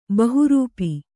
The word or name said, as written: ಬಹುರೂಪ